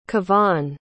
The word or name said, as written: kevon